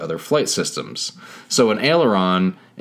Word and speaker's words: aileron: But it's a strange sounding word. – Aileron. – Aileron, but it sounds like the right way to pronounce it, I guess.